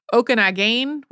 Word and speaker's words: Okanagan: And I actually found, as I was working on the pronunciations for that episode, a couple of different contradictory pronunciations for Okanagan. There was also Okanagan, which I think is a more like a Salish language pronunciation, and one that almost like didn't really have the final syllable on it at all. So I think there are some variations on that regionally.